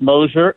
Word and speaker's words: Mosher: Am I pronouncing your name right, Stephen? – Yeah, it's Mosher, and the book is Bully of Asia.